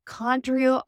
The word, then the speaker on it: Condrieu: So Condrieu on the label, which is spelled C-O-N-D-R-I-E-U.